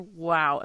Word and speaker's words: Wao: Now, let's talk about Oscar Wao.